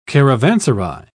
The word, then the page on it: caravanserai: British English